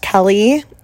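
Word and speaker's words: Keleigh: I can confirm her name is pronounced Kelly.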